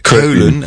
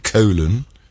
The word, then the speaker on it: Köln: We had Köln, as we pronounce it, Köln.